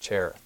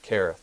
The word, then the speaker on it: Cherith: It's spelled with a K, and as Pastor always, when he taught through this, the CH is a hard K sound, so it's not Cherith, but Kereth.